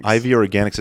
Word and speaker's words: IV Organics: So IV is like letter I. It's actually named after my girls, and very few people know this. My daughter's Isabella and Victoria, but most people see it as Roman numeral 4 Organics.